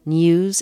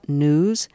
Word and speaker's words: news: Could Terry Gross be taught to pronounce N-E-W-S, news, instead of news? She doesn't say foo for few or moo for a cat's cry, does she?